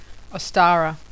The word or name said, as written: Ostara